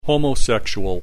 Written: homosexual

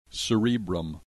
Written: cerebrum

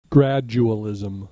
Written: gradualism

